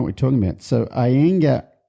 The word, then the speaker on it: Iyengar: Iyengar yoga.